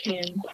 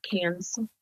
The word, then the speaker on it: Cannes: It's pronounced Cannes, not Cans.